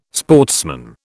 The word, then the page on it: sportsman: British English